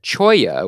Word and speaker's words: cholla: So now the word cholla, which is Cholla for listeners, you know, with that Spanish double L pronunciation, that word is, the etymology is unclear to me.